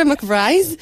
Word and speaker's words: McVries: Yeah, so if ever this is going to be edited out, I'm just going to pretend that I said it right the first time, which is Peter McVries.